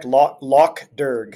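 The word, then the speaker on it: Lough Derg: Am I pronouncing that correctly? – That's correct. Loch Derg.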